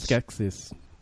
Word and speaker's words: Skeksis: A Yoda print and a Zexis. Is that how you pronounce Skeksis. – It? Skeksis.